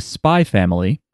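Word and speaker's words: Spy × Family: We watched the first 3 episodes of Spy Family. I know you expected the X, but apparently you don't pronounce it.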